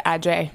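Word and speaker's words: Adjaye: a wonderful architect, graphic designer, works for, how do you say, Adage? – David Adjay.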